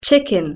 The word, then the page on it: chicken: British English